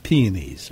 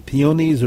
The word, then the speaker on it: peonies: Are they peonies or peonies? – Yes. Oh. You can do either one. One is more British, one is more American. They're both fine. It's like clematis or clematis. You can do either one. In the United States, normally we are emphasizing the second syllable. In Britain, in England, it's the first syllable.